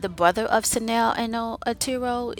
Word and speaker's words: Sanel: So we have Sanel, which is S-A-N-E-L.